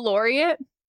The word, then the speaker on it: laureate: A Nobel laureate? – Laureate. Thank you. – Is that one word? – Mm-hmm. L-A-U-R-E-A-T-E. – And then Noble or Nobel? – Well, I was just saying see? – Lawyers. Laureate.